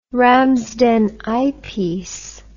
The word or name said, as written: Ramsden eyepiece